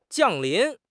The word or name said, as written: 降临